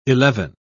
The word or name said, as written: eleven